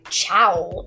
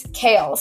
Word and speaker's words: Chaol: Like Kaol, it's spelled C-H-A-O-L, and I thought it was like something like chowl or whatever, but when I looked at the end, it said it's Kaol, so I'm like, oh, okay, and that was helpful.